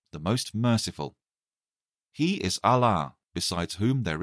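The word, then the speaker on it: Allah (الله): It is pronounced Allah with a long vowel A.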